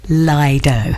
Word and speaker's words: Lido: I mentioned the word Lido, and Matt's emailed in and told me, no, it's Lido, as in D-O-U-G-H. You've got to pronounce it like that.